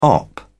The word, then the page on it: op.: British English